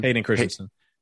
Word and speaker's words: Hayden Christensen: Hayden Christensen.